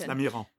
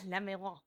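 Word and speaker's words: Lamirand: Yes, Lamiran. – Lamiran.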